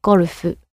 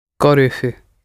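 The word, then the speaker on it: gorufu (ゴルフ): So the word for golf becomes gorufu. – Gorufu.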